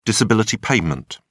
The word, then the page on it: disability payment: British English